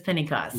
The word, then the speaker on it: Pennycost: Isaac Simmons also is Miss Pennycost. Is that the right way to say it? – Yeah. – Isaac's drag persona is Miss Pennycost.